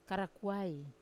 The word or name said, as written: karakuai